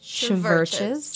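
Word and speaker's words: CHVRCHES: I believe it's pronounced Churches. Churches. – It's in all capitals. The U is a V.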